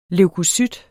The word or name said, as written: leukocyt